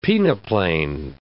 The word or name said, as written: peneplain